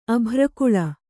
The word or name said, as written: ಅಭ್ರಕುಳ